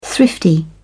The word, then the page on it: thrifty: British English